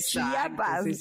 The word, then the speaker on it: Chiapas: Chiapas, Chiapas, que lo estaba pronunciando mal, tenía que decir Chiapas.